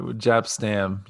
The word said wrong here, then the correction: Jaap Stam: Jaap Stam.